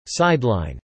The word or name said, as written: sideline